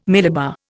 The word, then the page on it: millibar: Indian